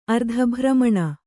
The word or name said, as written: ಅರ್ಧಭ್ರಮಣ